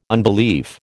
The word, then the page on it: unbelief: American English